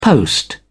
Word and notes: post: British English

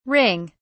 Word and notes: ring: British English

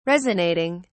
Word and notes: resonating: British English

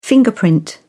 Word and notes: fingerprint: British English

